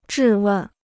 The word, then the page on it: 质问: zhì wèn